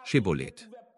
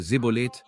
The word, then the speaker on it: Schibboleth: Dann sagten sie zu ihm, wenn du nicht Ephraim bist, sag Schibboleth. Aber er sagte Sibboleth, weil er nicht gut aussprechen konnte.